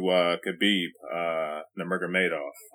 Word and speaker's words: Khabib Nurmagomedov: Up next, we're about to get into Khabib Nurmagomedov.